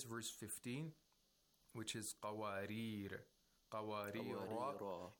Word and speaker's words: قوارير: If you connect this word to the next verse, there would be no tanween. قوارير قوارير. Okay, but if you stop, you have to pronounce it with alif al-mat. And the reason that this word is pronounced with alif al-mat is to so that the rhyme is the same as the other verses.